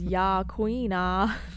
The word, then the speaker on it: Yaquina: I put the pronunciation on here, and I put it as Yaquina. – Yes, queen.